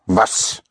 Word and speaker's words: was: Was, right. Spelled W-A-S. The W, again, is pronounced like a V in German, and you have was, was.